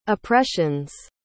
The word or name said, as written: oppressions